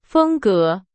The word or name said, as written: 风格